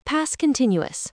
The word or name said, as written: past continuous